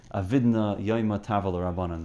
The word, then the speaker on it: Yoma: It used to be Yuma. Until Art Scroll came out. Daf Lamed Zayin Amad Aleph. And just Agav, the correct pronunciation is Yoma or Yoma if you want to be Yeshivish.